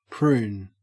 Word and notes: prune: Australian English

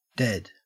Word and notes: dead: Australian English

